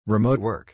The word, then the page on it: Remote Work: English